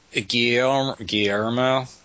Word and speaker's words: Guillermo: Yeah, it's like the Guillermo.